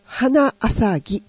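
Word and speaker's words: 花浅葱: この 3 つで花浅ぎって読むんですよ。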